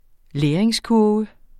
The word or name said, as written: læringskurve